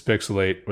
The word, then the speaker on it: PixelHate: This is Pixelate, where the H is silent, but the hate is real.